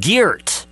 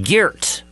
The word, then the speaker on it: Geert: I'm giving you the proper pronunciation here because it's spelled Geert. And you'll have people say that, Geert Wilders. It's Geert in Dutch.